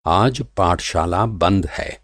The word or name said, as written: पाठशाला